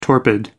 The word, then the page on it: torpid: American English